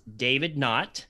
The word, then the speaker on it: Knott: His name is David Knott.